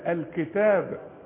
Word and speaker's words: الكتاب: الحروف دي مرققة، وكلمة الكتاب دائما هنا بتكتب بدون ألف الكتاب، وتنطق بألف كاملة بعد التاء الكتاب.